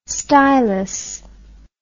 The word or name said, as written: stilus